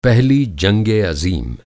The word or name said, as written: पहली जंगे अज़ीम